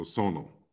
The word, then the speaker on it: Sonal: Hello, Sonal. Am I pronouncing it right? – Sonal. – Sonal. Like sonar, but with an L.